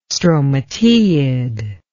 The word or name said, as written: stromateid